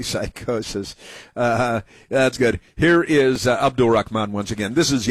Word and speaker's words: Abdul: We gotta say it like that, man. It's creepy. Abdul. – That's how it's pronounced. – Holds on to the L a little too long.